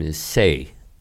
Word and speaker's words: Issei: Do you say Issei? Is that how you say it? – Yes, Issei.